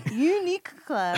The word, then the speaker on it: Uniqlo: Uniqlo.